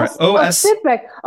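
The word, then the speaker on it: Oscypek: But it's a Polish smoked sheep milk cheese, I think called Osipek or Osipek.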